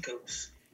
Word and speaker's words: Coates: And this was inspired by the New York Times bestseller Between the World and Me by Ta-Nehisi Coates. Is that how you say it? – Yes. – Okay. – Coates. – Yes.